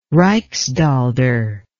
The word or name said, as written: rijksdaalder